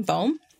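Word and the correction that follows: Bohen: Ian Bowen. – Bowen.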